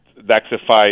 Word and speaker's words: Daxxify: I guess I should call it an alternative to Botox called Daxxify. Am I saying that correctly? – That is correct. Daxxify is the term that it goes by.